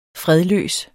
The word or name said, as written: fredløs